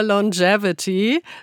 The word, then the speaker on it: Longevity: Wir reden über Longevity. So wird das ausgesprochen. Longevity, Langlebigkeit. Stimmt doch, oder? Longevity, habe ich richtig ausgesprochen? – Korrekt.